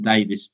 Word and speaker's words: Davies: But if you type in my name, which is Glynn Davies, make sure you spell it correctly, otherwise a very different book comes up. – All right. – That's spelled G-L-Y-N-N, and then Davies, D-A-V-I-E-S.